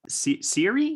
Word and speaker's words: Siiri: Can you talk to me a little bit about like just discovering Siri? Is that how I say her name? – Yeah.